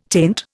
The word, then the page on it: taint: Indian